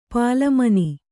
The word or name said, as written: ಪಾಲಮನಿ